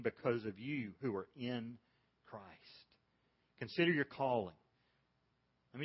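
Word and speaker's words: In (ἐν): Here's how you pronounce the word in Greek. In. Now, it would be spelled E-N.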